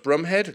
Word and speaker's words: Bromhead: I know it's spelled Bromhead, pronounced Brumhead, at least that's my understanding.